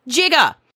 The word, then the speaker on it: giga: So NIST says that the G-I-G-A prefix is pronounced correctly, jigga.